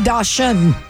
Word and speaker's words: dachshund: I say dachshund. – Because you're stupid. – No, because it's German, and I figured that's how they would pronounce it. – It's dachshund. Everything in German is hard.